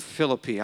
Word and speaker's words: Philippi: We're going to finish by talking about the city of Philippi, or the city of Philippi. I understand that's actually the correct pronunciation.